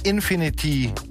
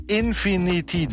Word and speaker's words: Infinity: Hier sind zunächst die Infinity. Ich wusste, dass ich einen zu viel spreche, eine Silbe. Infinity, doch ist richtig, Infinity Guitars, die Sleigh Bells.